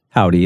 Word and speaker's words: Hovvdy: They spell it H-O-V-V-D-Y. So, of course, Howdy is how you get that.